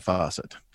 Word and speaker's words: Fawcett: And that's Fawcett, not spelled like the kitchen faucet. – Yes. Yeah. If you're old enough, it's spelled like Farrah Fawcett.